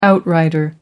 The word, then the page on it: outrider: American English